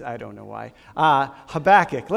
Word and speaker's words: Habakkuk: God loves you a whole lot, and this book is pronounced Habakkuk. So those are your notes.